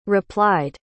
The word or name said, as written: replied